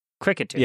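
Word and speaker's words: Kricketune: Cricketune? – Cricketune.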